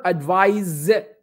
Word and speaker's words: advise: Advise should be with an, with an S. Okay. Advice, nasiha is with a C. The verb advise, pronounced Z with an S.